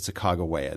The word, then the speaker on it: Sacagawea: Well, I pronounce it Sacagawea. This is something that people argue about. The reason many people pronounce it Sacagawea is that William Clark, in his manuscript journals, wrote her name in several different ways. And when it was published, the editor had to choose a spelling.